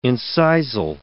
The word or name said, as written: incisal